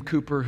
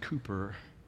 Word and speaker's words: Cowper: There was this man named William Cooper who was a hymn writer. He was a dear friend of John Newton's. And if you Google him, his name is spelled Cowper, but it was pronounced Cooper.